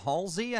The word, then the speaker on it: Halsey: Halsey, I think I'm pronouncing that right. – You did.